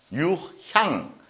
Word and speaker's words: 유향: 어쨌든, 그 12개 떡 위에 유황을, 거기에 보면은 뿌려놓게 돼 있죠? 유황. 유향, 유황이 아니고 유향이죠. 발음을 좀 잘해야 될것 같아요.